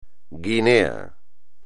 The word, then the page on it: Guinea: espagnol